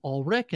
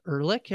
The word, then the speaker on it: Ulrich: I promise I'll pronounce it Ulrich and not Ehrlich, everybody.